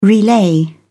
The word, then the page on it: relay: British English